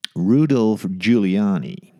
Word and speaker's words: Rudolph Giuliani: Rudolph Giuliani. Zo spreek je dat uit.